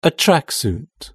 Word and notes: a tracksuit: British English

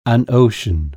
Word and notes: ocean: British English

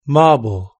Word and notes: marble: British English

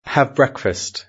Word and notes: have breakfast: British English

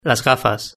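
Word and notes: las gafas: Spanish (Spain)

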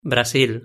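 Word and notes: brasil: European Spanish

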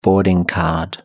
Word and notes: boarding card: British English

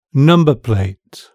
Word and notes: number plate: British English

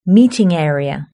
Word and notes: meeting area: British English

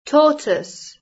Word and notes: tortoise: British English

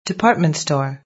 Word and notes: department store: British English

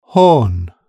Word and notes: horn: British English

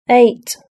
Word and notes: eight: British English

